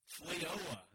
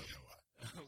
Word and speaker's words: FLEOA: FLEOA. There we go. – FLEOA.